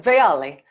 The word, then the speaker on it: Veali: And, you know, I always tell people like pronounce it kind of like it's Italian. Veali.